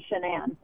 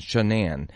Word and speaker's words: Shanann: She pronounced it Shanann. – Oh, Shanann. – Yeah, it's like, because the last part of it is Anne, like the name Anne, Shanann.